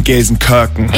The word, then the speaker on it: Gelsenkirchen: Entschuldigung, Gelsenkirchen.